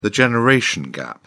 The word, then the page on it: generation gap: British English